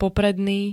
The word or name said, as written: popredný